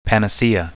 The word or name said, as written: panacea